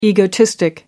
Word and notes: egotistic: American English

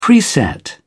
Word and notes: preset: British English

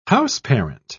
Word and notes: houseparent: British English

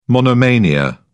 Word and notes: monomania: British English

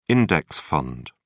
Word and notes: index fund: British English

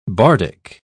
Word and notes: bardic: British English

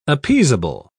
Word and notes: appeasable: British English

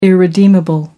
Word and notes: irredeemable: American English

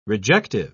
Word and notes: rejective: British English

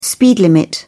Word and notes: speed limit: British English

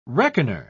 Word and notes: reckoner: British English

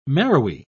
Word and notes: marrowy: British English

